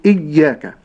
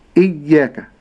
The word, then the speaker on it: إياك: هذه الياء ليس فيها شدة، أي ليس فيها قوة، ولكنها لينة، فبعض من يقرأ الياء بالشدة فيقول إياك إياك، هذا خطأ، ولكن إياك إياك.